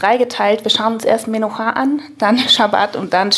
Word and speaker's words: Menucha: Ja, habe ich mir schon fast gedacht, dass ich das nicht so deutsch aussprechen darf. Okay, Menucha.